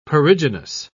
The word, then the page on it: perigynous: British English